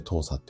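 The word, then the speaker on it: 糖鎖: まあ、これをね、糖さなんて言ったりするんですけれども、砂糖の糖に連鎖の差で糖さって読む読むんですが、いわゆる共食い始めちゃいます。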